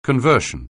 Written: conversion

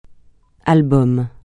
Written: album

